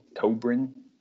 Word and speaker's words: Cobrin: I've got Allie Cobrin, spelled like Corbin, but reversed the R&B, Cobrin.